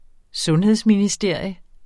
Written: sundhedsministerium

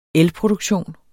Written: elproduktion

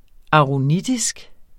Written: aronitisk

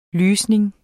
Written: lysning

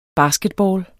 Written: basketball

